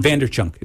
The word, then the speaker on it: Vaynerchuk: Vaynerchuk.